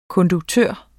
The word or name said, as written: konduktør